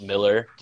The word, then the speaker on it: Millar: I remember reading in a Wizard interview saying that his name was pronounced Miller, despite how it was spelled, but now everyone pronounces it Millar, so I don't even know what to fucking think anymore.